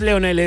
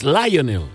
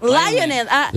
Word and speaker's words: Lionel: No es Leonel, es Lionel. – Lionel.